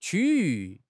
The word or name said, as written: 区域